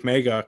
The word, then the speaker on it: Meiga: And Malik Mega could be that guy, right? He is the nickname Megatron, spelled like his last name, M-E-I-G-A, is not given, lightly.